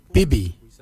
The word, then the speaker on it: bibi: Your bill is spelled B-I-L-Y, bill. The nipple, we say bibi. Bibi is spelled B-I-B-I, bibi, for your nipple.